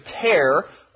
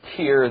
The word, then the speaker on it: tear: And that word tear, you could also read or pronounce it tear, as in a tear that falls from your eye when you're crying. But here it's tear, which means Webster's web was tearing into two.